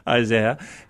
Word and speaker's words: Isaiah: He's in church reciting from the prophet Isaiah, as the British say, Isaiah, and it's showing one of his fellow British teammates falling and just being unable to get back up.